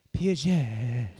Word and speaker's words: Peugeot: Is it Peugeot? – Peugeot. – Peugeot. – Peugeot. – Yeah, I think that T is silent. – Puget.